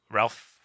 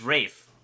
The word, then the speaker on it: Ralph: I'm looking up how to say Ralph. – It is Rafe.